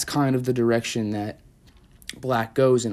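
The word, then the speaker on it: 6LACK: So on the contrary, on the other side of relationships, you have the artist named Black, commonly said as Six Lack, but it's pronounced Black.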